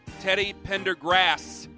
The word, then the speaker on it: Teddy Pendergrass: Teddy Pendergrast. – Teddy Pendergrass. There's no T at the end. It's not Sean Pendergast.